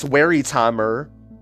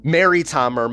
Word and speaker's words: Wertheimer: and even Max Wertheimer. Wertheimer, my bad, I'm sorry for pronouncing that wrong.